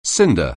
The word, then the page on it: cinder: anglais